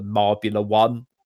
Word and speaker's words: Marbula One: You want to look for Marbula One, it's pronounced.